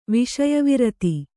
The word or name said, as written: ವಿಷಯವಿರತಿ